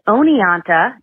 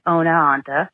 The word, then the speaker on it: Oneonta: It is Oneonta, I think you said Oneonta, and it's Rensselaer, not Rensselaer.